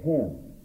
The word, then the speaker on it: Hinn: There's a preacher down there that has a congregation of around 3,000 that meets 3 times every Sunday by the name of Benny Hinn. Though he seems to pronounce it more like Hen. – sort of H-I-N-N, the miracle mind.